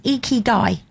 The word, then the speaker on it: Ikigai: Okay, so this is, and it's pronounced Ikigai.